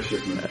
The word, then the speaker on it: Schifrin: Number 18, Jaws, Lalo Schifrin. I think you pronounce that. My apologies if I'm wrong. – Yeah, Schifrin.